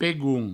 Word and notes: pegon: pegoun; provencal

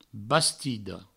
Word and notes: bastida: Provençal; bastido

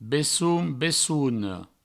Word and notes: besson, bessona: Provençal; bessoun, bessouno

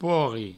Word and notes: porri: provençal

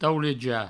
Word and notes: taulejar: provençal; tauleja

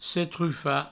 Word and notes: setrufar: setrufa

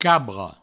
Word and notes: cabra: cabro

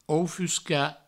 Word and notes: oufuscar: oufusca

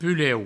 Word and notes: pusleu: puleu; provençal

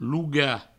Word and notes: logar: provençal; louga

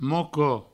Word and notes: moquo: mouco

